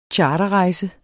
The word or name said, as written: CHARTERREJSEN